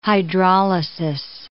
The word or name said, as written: hydrolysis